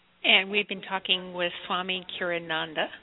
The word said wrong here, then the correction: Kriyananda: Is that how you say your last name? – Well, thank you for asking. You got it pretty close, but it's Swami Kriyananda. – Kriyananda. Thank you.